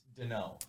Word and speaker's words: Deneau: Yes, it's Deneau.